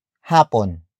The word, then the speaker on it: hapon: Hapunan is spelled as H-A-P-U-N-A-N. It means dinner. Hapunan comes from the root word hapon. Hapon. Hapon, which is spelled as H-A-P-O-N.